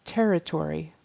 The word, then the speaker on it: territory: And territory, syllable stress on the first syllable, territory.